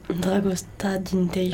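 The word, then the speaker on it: Dragostea din tei: Donc m'a aiguillée et elle a choisi euh, Dragosti Dintei, je sais pas comment ça se prononce, je sais pas si c'est comme ça. Dragosta Dintei. – Ah merci.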